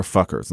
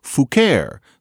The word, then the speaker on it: Fouquer: And already by this time, it would have been pronounced not Fucker, but Fouquer.